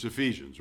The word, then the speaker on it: Ephesians: And if you put the accent on the wrong syllable, then it would be Ephesians. But it's Ephesians, right?